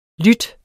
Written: lydt

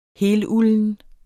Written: helulden

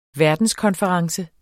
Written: verdenskonference

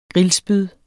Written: grillspyd

